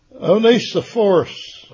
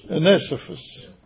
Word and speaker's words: Onesiphorus: it's Onesiphorus. – Onesiphorus.